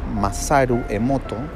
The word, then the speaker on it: Masaru Emoto: Ella, em, de hecho, tú aprendes mucho con, con ella respecto al tema de Masaru Emoto, si no me equivoco, está bien pronunciado, es japonés, Sí, se está bien pronunciado.